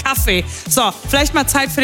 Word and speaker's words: Kaffee: Kaffee. Oh, is that how you say it? – Kaffee.